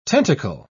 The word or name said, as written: tentacle